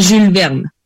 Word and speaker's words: Jules Verne: Se dice Jules Verne.